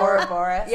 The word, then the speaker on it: Ouroboros: Ouroboros. – Yeah, the Ouroboros, because we could never prove which of us was feeling that.